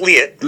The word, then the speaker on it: Lehet: Lehet.